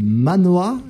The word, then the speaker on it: manhwa: Une exposition dont vous êtes à l'initiative sur la bande dessinée chinoise, le manhwa, je prononce bien.